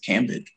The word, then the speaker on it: Cambage: her name is Liz Cambage, I believe it's pronounced. – Oh, I think it's Cambage, but yeah.